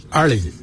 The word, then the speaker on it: ali: In our ancient language, we say, ali. It's spelled A-L-I.